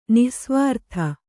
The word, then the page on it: ನಿಃಸ್ವಾರ್ಥ: nih svārtha